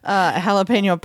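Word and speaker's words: jalapeño: Jalapeno poppers. And that was white. – At least you didn't pronounce the J.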